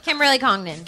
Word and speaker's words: Kimberly Congdon: Kimberly Congdon. – Kimberly Congdon, spelled like it sounds. C-O-N-G-D-O-N.